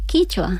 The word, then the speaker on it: quichua: Claro, por eso me has dicho, ay, yo pensaba que a lo mejor que lo había pronunciado mal, o sea, no. – ¿Qué es que es diferente el quichua? – Sí, sí, el quichua es de Ecuador y el quechua es de Perú.